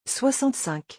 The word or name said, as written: 65